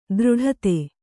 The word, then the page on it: ದೃಢತೆ: dṛḍhate